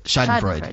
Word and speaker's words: Schadenfreude: But well, there is an element of, and I always pronounce this word wrong, Schadenfreude. To this? – Schadenfreude? I don't know. – Schadenfreude, yes.